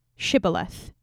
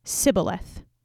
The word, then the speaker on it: Shibboleth: He captured the Jordan, and then anytime they tried to cross over, he would ask them to say the word Shibboleth, which the Ephraimites pronounced as Sibboleth. You know, slightly different accent.